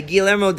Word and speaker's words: Guillermo: Yeah, Guillermo Del Toro.